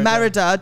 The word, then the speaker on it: Merida: Merida.